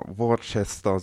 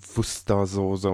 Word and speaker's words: Worcester: Sojasauce oder ich scheue mich jetzt vor Worcester Soße. Ja, ich weiß, es wird irgendwie Wustersoße oder so ausgesprochen.